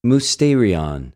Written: μυστηριον

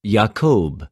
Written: Ἰακωβ

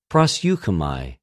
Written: προσευχομαι

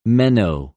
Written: μενω

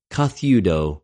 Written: καθευδω

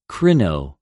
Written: κρινω

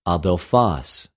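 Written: ἀδελφος

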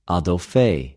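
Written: ἀδελφη

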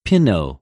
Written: πινω

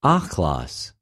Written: ὀχλος

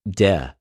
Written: δε

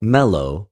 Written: μελλω

